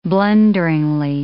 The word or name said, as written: blunderingly